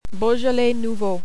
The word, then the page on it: Beaujolais nouveau: French